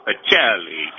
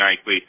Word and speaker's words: Eichley: Of course, I want to pronounce it Acelli, you know, getting that Italian flavor in there. But we'll go with Christine Eichley is coming on.